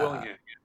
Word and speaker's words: Willingham: yourself and Bill Win- Bill Winningham is that how we say his name? Because I'm sorry if we butcher his name. Yeah, Bill uh, Willingham.